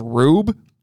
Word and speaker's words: R/B: All right, 2018, Ultraman, it's not R/B, it's actually pronounced Rube.